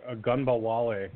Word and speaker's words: Ogunbowale: I'm fake pronouncing Ogunbowale. – Dare Ogunbowale. Now, you're not a Wisconsin Badgers fan, but I am, so I know how to pronounce his name.